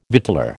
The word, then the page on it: victualler: American English